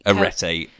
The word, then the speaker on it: Arete: It's A-R-E-T-E. Yeah. – Wait. – Arete way.